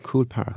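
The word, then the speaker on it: Coole Park: a place called Cool Park. – Huh. Cool Park. – Cool Park. – How do you spell that? – C-O-O-L-E, Park.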